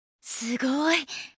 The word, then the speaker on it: すごい: 스고이 스고이는 대단하다는 뜻으로 놀라거나 감탄하거나 감동했을 때 쓸 수 있는 말입니다.